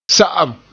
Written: sa'am

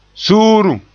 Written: suurum

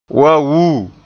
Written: waa wuu

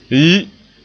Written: yi

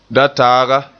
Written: dataaga

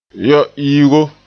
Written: yo-iigo

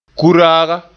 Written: kuraaga

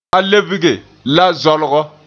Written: lebge

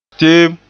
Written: teem

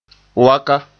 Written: waka

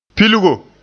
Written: pilgo

